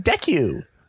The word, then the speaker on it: Deku: No, you know how like Uraraka like pronounces Deku? It's like, Deku. – Yeah. – It's like with a U at the end.